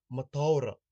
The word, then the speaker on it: mataora: And the last word is mataora. Mataora means happy in English. Mataora is spelt M-A-T-A-O-R-A.